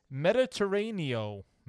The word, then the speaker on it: Mediterraneo: Number 11, Mediterraneo. – You said it right. You were good. – That's how you say that. That's those letters in that order. Makes that sound.